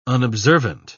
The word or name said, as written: unobservant